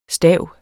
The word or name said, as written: stav